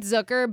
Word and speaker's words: Zucker: It's the same thing with Zucker, but it's the way that it's pronounced is like hooker with a Z.